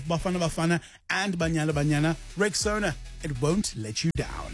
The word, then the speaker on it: Rexona: It's pronounced Rexona. Simple, strong, and easy to remember.